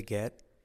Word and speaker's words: get: La T casi no se dice de get.